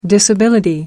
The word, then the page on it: disability: American English